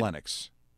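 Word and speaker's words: Kivlenieks: Matisse, how do you say his last name? Kivinex? Matisse Kivinex. That sound right? – Kivlenix. – Sorry? – Kivlenix. – Kivlenix. – Yes.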